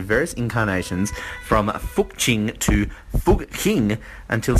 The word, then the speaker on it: Fucking: It is pronounced, it is spelt, I should say, F-U-C-K-I-N-G. So it's spelt fucking, but it is apparently pronounced Fuking.